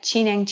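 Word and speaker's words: Zhineng: Yeah, Qineng Qigong, which is spelled as C-H-I or Z-H-I. It's spelled in two different ways. And Neng is N-E-N-G.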